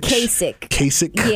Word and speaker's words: Kasich: And he really did that against, how you pronounce this man's name? – John Kasich? – Kasich. – Yeah, Kasich.